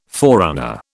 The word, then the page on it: forerunner: British English